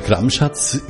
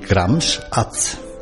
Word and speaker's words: Gramschatz: Der Name Gramschatz wird eigentlich falsch ausgesprochen. Das hat weder was mit Gram noch mit Schatz zu tun, wie man erst einmal jetzt vermuten würde, sondern der Wortstamm ist eigentlich Gramschatz.